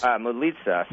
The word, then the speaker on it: Moulitsas: hey, about Hillary Clinton, with the time we have left, there was the op-ed in the Washington Post by, how do you pronounce this? Marcos? – Moulitsas.